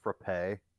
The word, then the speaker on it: frappe: It's actually, I bet people would come to our region, be like, it's actually not pronounced frap. It's pronounced frappe.